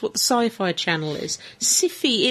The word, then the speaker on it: Syfy: How is it pronounced, El Presidente? – Definitely Siffy. – Siffy. – Siffy. – S-Y-F-Y.